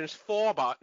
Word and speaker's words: Schwarber: Actually, it's old German pronunciation. Schwarber. No, yes, you got it right.